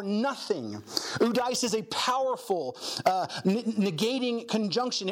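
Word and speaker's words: udais (οὐδείς): It's pronounced udais. Udais. Okay? Udais is a compound word of two other words.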